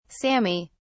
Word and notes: sami: British English

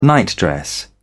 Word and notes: nightdress: British English

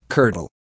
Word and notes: curdle: American English

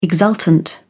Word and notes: exultant: British English

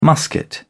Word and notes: musket: British English

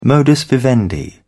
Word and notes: modus vivendi: British English

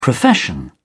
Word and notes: profession: British English

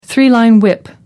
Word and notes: three line whip: American English

